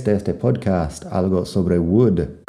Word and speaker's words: would: porque se escriben parecidas y todos tienen la L que no suena y el mismo sonido de la U corta. Así que would, could, should.